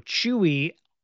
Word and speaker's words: Chiwy: And it literally said, you know, Chewy, similar to how you'd pronounce, you know, Chewbacca, like, so, but it's Chiwy is the spelling. So it, you know, but it would be pronounced Chewy or Chewy.